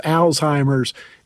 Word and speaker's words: Alzheimer: Alzheimer's, is there really a certain way to pronounce the disease? – Yeah. Well, typically named after Alois, Alzheimer, it is Alzheimer, but one of the names, you know, and we'll use this colloquial as well when we're discussing with people.